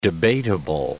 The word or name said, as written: debatable